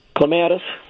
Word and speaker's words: clematis: How do you say it? Clematis? – Clematis. – Clematis is the correct way to say it.